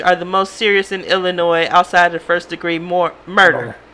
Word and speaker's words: Illinois: Why do you always pronounce that S? – Illinois. – It's Illinois.